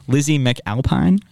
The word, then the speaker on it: McAlpine: Lizzie McAlpine, say that right? – Yeah.